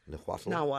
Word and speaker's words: Nahuatl: Nahuatl.